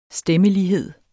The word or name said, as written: stemmelighed